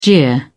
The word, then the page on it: jeer: British English